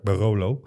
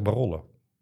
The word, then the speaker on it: Barolo: Ja, want eh, ik, bezon, eh, ik, ja, ik bezondigde mij ook net aan de beroemde uitspraak Barolo. – Mhm. – maar jij zegt barollo. – Ja. – Korte klank. – Barollo.